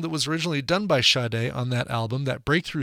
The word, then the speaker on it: Sade: Moving on to Sade, and I'm making sure to pronounce it Sade because during that first set, I looked it up. Epic Records was the record label that indeed put Sade, pronounced Sade, on the spines of the CDs that came out for Diamond Life. However, that was a mistake. Her name is really pronounced Sade.